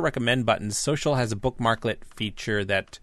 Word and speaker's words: Socl: And Microsoft launched their own social network over this last week called Social. Well, it's pronounced Social, but it's spelled S-O-C-L in, once again, like, another example of Microsoft kind of making things a lot more complicated than it needs to be.